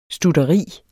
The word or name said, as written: stutteri